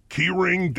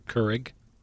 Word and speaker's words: Keurig: And I gotta say, it might damage the keyring downspout. – Keurig.